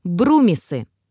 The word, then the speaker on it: Брумисы: Брумисы – произносится как «бру-ми-сы», ударение на первый слог.